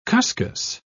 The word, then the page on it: cuscus: British English